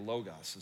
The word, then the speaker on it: logos: Or the logos is how you say it.